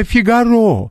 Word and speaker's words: Фигаро: Александр Сергеевич Пушкин в Евгении Онегине еще писал «Иль перечти женитьбу Фигаро». Это Фигаро. Это француз. Там всегда ударение на последний срок.